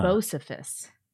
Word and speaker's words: Bocephus: Knuckle Hunky Buck, it's pronounced Bocephus. It's a nickname for Hank Williams Jr. I did not know, but now I know, so I will go with Bocephus.